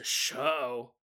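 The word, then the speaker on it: João: J-O-A-O. Blue Danuvrin 5852 said there's actually really no way to pronounce that in English, but the closest that you can come up with is Joe Uh-oh. But then you have to pronounce the J like a sh. So show. Show.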